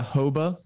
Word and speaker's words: Hoba: Yeah, Hoba in Namibia.